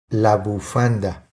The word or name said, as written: La bufanda